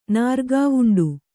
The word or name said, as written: ನಾರ್ಗಾವುಂಡ